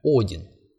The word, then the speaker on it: Один: Однако, если поставить ударение правильно, Один. Не один, а Один.